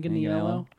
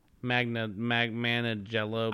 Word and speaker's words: Manganiello: Wait, how did you say his last name? Manganiello? – Manganiello. – I say Manganiello.